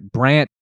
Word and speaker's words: Brant: And the tight end, Brent, was it Brent or Brant? Let me make sure I get that right. Brant Keithy, spelled like K-U-I-T-H-E. Brant.